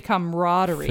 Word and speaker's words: camaraderie: So some people will say camaraderie. – Familiarity. – But there are a lot of syllables in it that are all supposed to be pronounced.